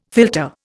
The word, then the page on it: philtre: Indian